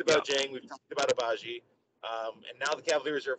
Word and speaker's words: Agbaji: And then at 13, you got Ocha Ajabi going to the Charlotte Hornets. So, Agbaji. You don't pronounce the G. – Agbaji.